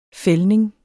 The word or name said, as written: fældning